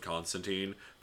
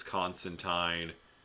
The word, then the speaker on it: Constantine: like one of the original fucking writers of Constantine. By the way, we've been saying it wrong this whole time. It's Constantine.